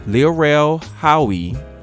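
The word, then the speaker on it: Lil Rel Howery: We're told the Migos rapper chipped in $5,000 for the service, with Lil Rel Howie, I hope I said that correctly.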